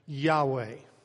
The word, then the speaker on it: Yahweh: After years of scholarship and a lot of people learning a great deal, we've come to understand that really it should probably be pronounced Yahweh.